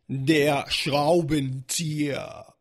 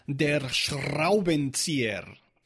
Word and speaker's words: der Schraubenzieher: hoy tenemos una palabra y es der Schraubenzieher. O con la pronunciación más a la suiza sería der Schraubenzieher.